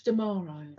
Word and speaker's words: D'Amaro: Josh D'Amaro.